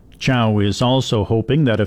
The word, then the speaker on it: Cao: The email address is Tate, that's T-A-T-E, at Chow, which is spelled C-A-O, at usask.ca.